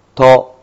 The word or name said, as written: to